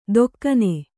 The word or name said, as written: ಧೊಕ್ಕನೆ